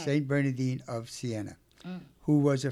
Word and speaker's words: Bernardine: Okay, so let's talk about Saint Bernardine. Oh, am I saying that correctly? – Bernardine. – Bernardine, D-I-N-E.